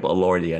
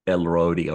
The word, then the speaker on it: Elordi: I just realized it's Jacob Elordi and not Elrodi or whatever it is I was calling him.